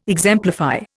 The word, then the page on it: exemplify: Indian